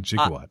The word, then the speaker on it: gigawatt: Because jumping gigawatt. He pronounced gigawatt wrong the entire film. – Gigawatt with a J.